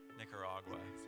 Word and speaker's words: Nicaragua: It's a bit of a tough one for the Aussies. I've noticed it's Nicaragua. Nicaragua.